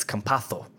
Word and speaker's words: Campazzo: And if we're going to get real technical about the pronunciation of his name, because if we're going to do the Spain pronunciation, it's Campazzo. You have to say it like a TH. It's not a Z. It's Campazzo. With a lisp.